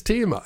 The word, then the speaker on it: Thema: Das ist ein schwieriges Thema. – Ah, falsche Betonung. – Ja, so klang es im Original Thema, nicht. So hast du es gesagt.